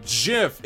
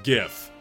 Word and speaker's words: GIF: At Aaron Pulvoy, is it pronounced GIF or JIF? It's pronounced GIF. It's a hard G. It's a hard G. It's not GIF. It's not friggin' peanut butter.